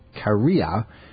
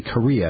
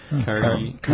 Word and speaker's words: Kariya: They're blaming a 13-year-old girl, actually, from the city of Kariya. It's not really Korea. It's spelled K-A-R-I-Y-A. I'm sure it's not pronounced Korea. How about Kariya? What do you think? That's probably better. Kariya. I just did. K-A-R-I-Y-A.